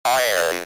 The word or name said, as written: iron